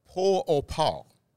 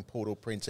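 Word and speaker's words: Port-au-Prince: And the French pronunciation, and I'll probably slaughter this, but it's Port au Par. Port au Par. Wow. Is how you say it with a French, Port au Par. Now, right. If I had called it that in the run, punters would have been scratching their head and saying, what's he on? But Port au Par is how you say it if you were speaking about the capital of Haiti in the French accent.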